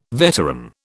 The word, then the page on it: veteran: British English